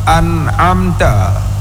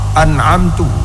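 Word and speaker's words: an'amta (أنعمت): Fa in ghayyara makna, seandainya lahen tersebut dapat merubahkan makna, seperti pengucapan kalimat an'amta dibacanya dengan dhummah an'amtu, au kasri, atau dibaca dengan kasrah an'amti, niscaya batalah salat man amkannahu ta'allum, batalah salat orang-orang yang mungkin belajar.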